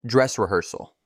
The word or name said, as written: Dress reharsal